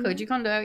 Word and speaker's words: Koji Kondo: Sheik's theme was composed by Koji Kondo. Am I pronouncing that name? – Koji Kondo, yep.